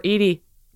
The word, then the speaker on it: Edie: And I was like, oh, I got to get more Edie.